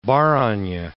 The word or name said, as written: Barany